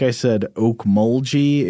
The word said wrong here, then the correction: Okmulgee: It's apparently Okmulgee with a hard G.